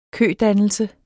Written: kødannelse

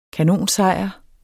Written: kanonsejr

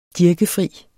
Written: DIRKEFRIE

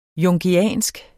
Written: jungiansk

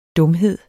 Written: dumhed

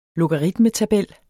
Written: logaritmetabel

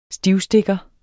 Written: STIVSTIKKERNES

